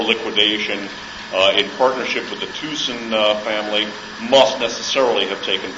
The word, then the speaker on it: Thyssen: That's actually the correct pronunciation of T-H-Y-S-S-E-N, which is a German, a Dutch name. The Dutch, I'm told, pronounce Y's like U's, and the Germans pronounce double S like a Z, so it's actually pronounced Thyssen.